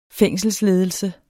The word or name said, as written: fængselsledelse